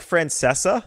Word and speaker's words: Francesa: Say his name. – Mike Francesa? – No. No, you said it right.